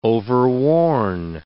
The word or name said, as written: overworn